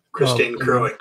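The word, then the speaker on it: Kristin Kreuk: Christine Kruk, yeah.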